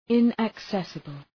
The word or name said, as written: inaccessible